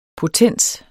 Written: potens

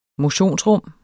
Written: motionsrum

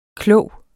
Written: klog

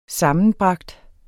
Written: sammenbragt

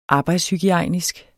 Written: arbejdshygiejnisk

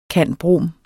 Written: cand.brom.

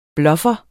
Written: bluffer

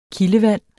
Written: kildevand

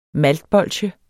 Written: maltbolsje

